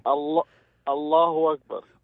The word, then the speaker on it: Allahu Akbar: Allahu Akbar.